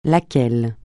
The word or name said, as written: lequel